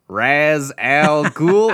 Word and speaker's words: Ra's al Ghul: Ra's al Ghul. Am I pronouncing that right, David? Mr. Ra's al Ghul.